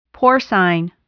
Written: porcine

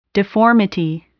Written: deformity

